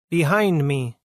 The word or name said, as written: Behind me